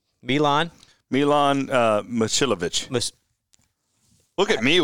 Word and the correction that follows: Momcilovic: Of course, Momcilovic. – See, I was wrong. This is why you don't rely on me with the pronunciations.